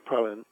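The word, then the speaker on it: Praslin: Praline would probably, some say Praline, and some say Praline. It's pronounced so many ways. I just say Praline. – Pauline. – It's P-R-A-S-L-I-N. – Okay. – Because I hear so many people, locals, calling praline. And so that's how I say it.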